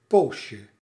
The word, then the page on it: instant: /ɛ̃s.tɑ̃/; France